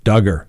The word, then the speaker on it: Duggar: As always, we'll continue to follow this story, and we're going to make sure we pronounce his name Duggar. That's right. Rhymes with hugger.